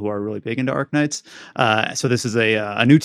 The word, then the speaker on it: Arknights: Arknights. And I know Arknights is Arknights? I always pronounce it Arknight. I don't know if the K is like... – It's Arknights, yeah.